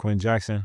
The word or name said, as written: Quenton Jackson